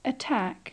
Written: attack

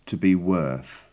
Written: to be worth

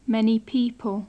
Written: many people

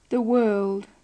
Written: the world

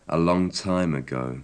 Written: a long time ago

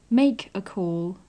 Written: make a call

